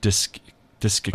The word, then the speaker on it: discectomy: It's called a discectomy. So it's not a discectomy. It's a discectomy.